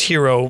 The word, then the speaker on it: gyro: Okay, well, he, but I'm talking about the gyro, which is pronounced hero by certain.